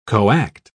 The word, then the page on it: coact: British English